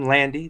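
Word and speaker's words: Landi: Last name, Landy. That's L-A-N-D-I, not Y.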